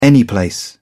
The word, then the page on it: anyplace: British English